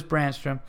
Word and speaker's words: Brandstrom: By the way, the perfect name for your listeners is the Zookeepers the, the name's pronounced just like Philip and Brandstrom. Okay, so I kind of I said Philippe or Philip, so Philip Julius Brandstrom uh, is like the department store Nordstrom's. So Brandstrom's. Okay.